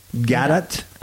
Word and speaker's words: Gadot: Is it Gadot? – Gadot.